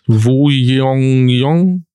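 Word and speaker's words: Jeong Woo-yeong: Wie spricht man ihn aus? Wo Yong Yong? Genau. genau so.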